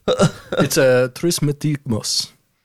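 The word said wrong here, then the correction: Trismegistus: by the way. You definitely didn't pronounce that correctly. – Oh, no, I put the T before the G. You're right. – Yeah, that was not real. – Trismegistos. There we go.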